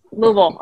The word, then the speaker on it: Louisville: She's like, no, say it correctly. I was like, okay. Louisville.